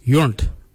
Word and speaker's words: yoornd: Mira is spelled M-I-R-D-A. The next colour is yunt. Yunt is yellow. Yunt. Yunt is spelled Y-O-O-R-N-D.